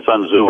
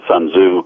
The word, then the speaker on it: Sun Tzu: Americans tend to pronounce it as Sun Tzu because that's how it looks to us. The Chinese pronounce it Sun Tzu, But since most English speakers pronounce it and identify it as Sun Tzu, we use that pronunciation.